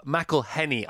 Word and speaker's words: McElhenney: It's Rob McElhenney. I say McElhenney because I drop my H's because I've always dropped my H's, but you don't.